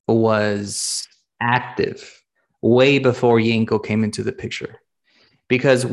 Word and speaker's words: Yenko: And there was this specific Camaro brand that was a Yenko version Camaro, and you said it right.